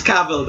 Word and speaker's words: Cavill: It's Cavill, though, right? – He said it himself.